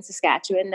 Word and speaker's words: Saskatchewan: Okay, so we did grow up in Ontario, but we are living in Saskatoon, Saskatchewan now. That's how you say it, Saskatchewan. It's not Saskatchewan. Saskatchewan.